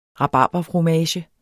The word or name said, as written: rabarberfromage